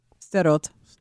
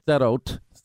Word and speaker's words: Sderot: I won't say it right. You will. Salot. Sderot. Sderot. Sorry. Uh, and we saw the, they they put in glass, BBs, metal to, to Just maximize anything, yeah.